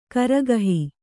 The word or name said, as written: ಕರಗಹಿ